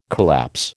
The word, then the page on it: collapse: American English